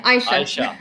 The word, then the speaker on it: Aisha: It's Aisha. – Aisha. – Aisha. Aisha. Yeah. – Apologies. Apologies. – The A is silent. It's confusing.